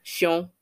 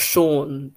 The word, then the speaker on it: Shion: Shion is not Sean, which is what we know many Sean's name themselves as in um, English society or Western society because it's harder for most people to pronounce Sean. But every time Femi said her brother's name,